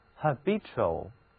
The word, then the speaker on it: habitual: 这里的字母 t 读音是 ch，发音的时候嘴唇要稍微向外翘一点点，这样才能标准发音，读作 ch， habitual。